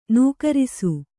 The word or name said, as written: ನಕಾರಿಸು